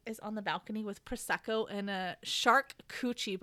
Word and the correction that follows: charcuterie: board. – Mm-hmm. – Charcuterie board? – Yeah. – I can never say that correctly.